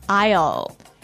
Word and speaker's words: aisle: A-I-S-L-E. – Aisle. – Aisle. It's a tricky word. It sounds like the contraction of I will. – Yeah, but it's spelled so strangely. – Right. We don't pronounce the S. The S is silent.